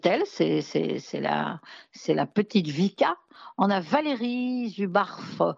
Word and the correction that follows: Zubarov: Non, attends, que je l'écorche pas. Valériou, parce que c'est Valériou. Zubarov.